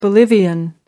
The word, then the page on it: bolivian: American English